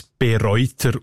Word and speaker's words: Bereuter: Ich habe dann aber gesehen, dass da erst gleich B beim Namen Bereuter kein Präfix ist, sondern dass es betont wird und in der Mundart mit einem langen E ausgesprochen B. Und der ganze Name mundartlich Bereuter.